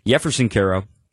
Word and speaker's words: Jefferson Caro: The J is silent, Spanish pronunciation, Jefferson Caro.